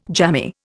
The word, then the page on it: jammy: Indian